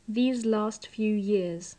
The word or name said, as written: these last few years